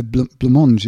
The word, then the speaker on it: Blancmange: So, yes, Blancmange. How do you spell? Have you got the name of the band there? – Blancmange. Because it's a bakery. – Yes, it's a blancmange.